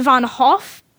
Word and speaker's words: Van Gogh: It's actually pronounced Vincent von Hoff because our prisoner,